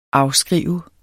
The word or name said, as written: afskriver